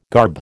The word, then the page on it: garb: American English